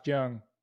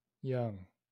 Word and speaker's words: Jung: So you don't pronounce the J. – Nope. – Josh Jung. – Young. – I'm still going to pronounce it. Young. – Young is Y. It starts with a Y, but this is with a J.